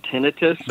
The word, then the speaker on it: tinnitus: People say tinnitus and tinnitus. I think it's tinnitus, isn't it? – I say it's, you know, doctors pronounce it tinnitus. – They do, yes. – Tinnitus is also an acceptable pronunciation, and to me, that's actually more accurate because tonight it's us every night.